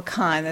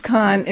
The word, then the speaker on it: Cann: Mrs. Cann or Kahn, the name is spelled C-A-N-N. Mrs. Kahn in October complained of, and I quote, a long-continued intermittent fever.